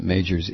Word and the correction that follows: Meijer: It's Meijer. Meijer.